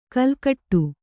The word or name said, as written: ಕೆಲಕಡೆ